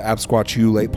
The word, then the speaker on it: absquatulate: The absquatulate part is T-U. It's not really ch, like C-H. It's, so let me spell it for you. It's A-B-S... Well, A-B, the word squat, U-L-A-T. Absquatulate. But the T-U makes a chew.